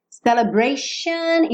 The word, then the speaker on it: celebration: Celebration, isso mesmo, essa pronúncia aí com X, celebration. Tá? É muito comum as palavras que terminam em T e O N, você vai ter esse shun, ok? Shun. Alright?